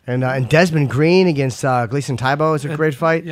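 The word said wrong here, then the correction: Tibau: Yep, T-Bow. – T-Bow. Oh. I always get his name wrong.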